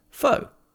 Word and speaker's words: pho: So it's spelled P-H-O, pho, but it's actually pronounced pho, as in F-U-H.